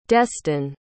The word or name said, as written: destin